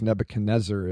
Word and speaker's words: Nebuchadnezzar: Yeah, that's, there, that's it's a there are a couple of ways to pronounce his name, and Nebuchadrezzar is hmm. – probably the more accurate one. But of course, Nebuchadnezzar is so popular, it's hard to run against it.